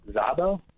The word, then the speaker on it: Szabo: and that's Anezka S-Z-A-B-O. How do you say her name? – Anezka Zabo?